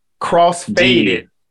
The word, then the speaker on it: crossfaded: Is it faded, faded, or fated? – Crossfaded. Crossfaded. F A D E D.